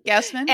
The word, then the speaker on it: Gausman: It was actually a retweet from Kevin Gausman. Did I say that right? That Orioles pitcher dude whose shirt I have. – Gausman.